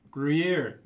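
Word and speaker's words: Gruyère: Gruyere.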